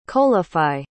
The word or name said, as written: coalify